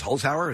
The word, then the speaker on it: Holzhauer: I think also the situation on Jeopardy now with James Holzhauer, is that how it's pronounced? – Yeah.